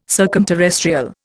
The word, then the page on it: circumterrestrial: Indian